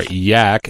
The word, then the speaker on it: YAAC: He says, YAAC, or YAC as he pronounces it,